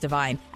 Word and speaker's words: Devine: Adam, it's actually pronounced Divine.